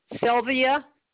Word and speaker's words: Sylvia: Hawley is H-O-L-L-Y. – And what's your first and last name? – Sylvia, S-Y-L-V-I-A, Hawley, H-O-L-L-Y.